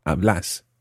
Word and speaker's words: hablas: So, vos hablas, with a stressed second syllable, hablas.